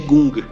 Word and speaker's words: Kung: E o Bruce Lee perguntou por que, que ele escolheu a pronúncia né, de Kung em vez de Gung. Ele falou que preferiu Kung porque era muito mais fácil para os ocidentais assimilarem a pronúncia.